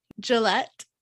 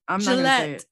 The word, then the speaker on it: Gillette: So this woman, Gillette, that's pretty much how you pronounce the like razor company. – Gillette, the best a man can get.